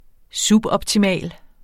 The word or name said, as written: suboptimal